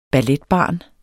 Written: balletbarn

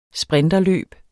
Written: sprinterløb

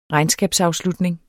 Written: regnskabsafslutning

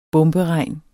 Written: bomberegn

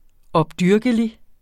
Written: opdyrkelig

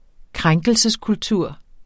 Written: krænkelseskultur

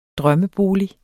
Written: drømmebolig

